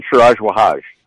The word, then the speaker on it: Siraj Wahaj: Is that the way you say it? – Siraj Wahaj. – Okay, Wahaj and his supporters.